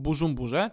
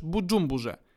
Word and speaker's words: Bużumburze: Saidi urodził się 1 maja 1987 roku w Bużumburze, czy też Budżumburze – Wymowa może się nieco różnić w zależności od tego, czy stosujemy wymowę bardziej francuską, czy też tę w języku Kirundi.